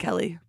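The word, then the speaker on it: Keleigh: Kaylee Sperry, was also... – It's Kelly Teller. – It's Kelly Teller? – I thought it's Kaylee Sperry. – I thought that too, but you pronounce it Kelly.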